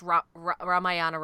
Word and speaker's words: Ramayana: So they did the Ramayana. Okay, I totally said that wrong because it's Ramayan, but I'm whiting, I'm Americanizing the word up so you can actually find it.